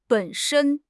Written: 本身

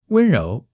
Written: 温柔

